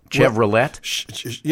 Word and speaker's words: Chevrolet: That's right, and people will love a Chevy more than they will love a, how do you pronounce this? Chevrolet? – Yeah, and that's just some old French.